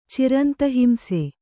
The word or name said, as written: ಚಿರಂತಹಿಂಸೆ